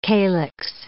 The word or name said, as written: calix